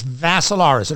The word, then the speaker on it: Vassilaris: D-I-M-I-T-R-I. And the last name is Vassilaris. It rhymes with Asilaris, but you add a V.